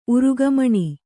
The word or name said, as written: ಉರುಗಮಣಿ